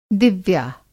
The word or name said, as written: दिव्या